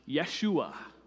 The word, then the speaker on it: Yeshua: In the first century of Palestine, you would probably hear it pronounced Yeshua. Was probably more how they said it.